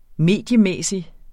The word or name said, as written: mediemæssig